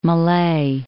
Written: Malay Archipelago

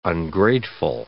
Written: ungrateful

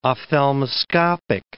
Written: ophthalmoscopic

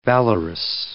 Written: valorous

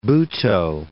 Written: Bhutto

